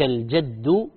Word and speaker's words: الجد: طيب، يقول أيضا السؤال ما معنى ولا ينفع ذا الجد منك الجد، وهل تنطق بفتح الجيم أم بكسرها؟ الجواب لا ينفع ذا الجد منك الجد بفتح الجيم،